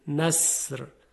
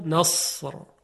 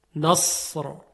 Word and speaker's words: نصر: ببینید اگر سین نصر با سین، اگر کلمه نصر با سین بود چگونه میگفتیم؟ نصر. حالا همین رو درشت کنید صدا رو، نصر نصر، میبینیم که صدای صاد خیلی درشت تلفظ میشه.